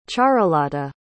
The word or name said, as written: charulata